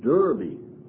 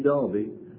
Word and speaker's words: Derby: Now, for those of you who don't know where Derby is, you probably even think it's pronounced Derby. But it is actually Derby.